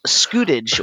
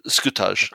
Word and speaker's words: scutage: And here's my section about entrepreneurialism and the medieval form of debtorship called scutage or what is that? T scoot? I don't even know how to pronounce that. – Yeah, scutage. – Scutage. Thank you.